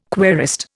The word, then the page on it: querist: Indian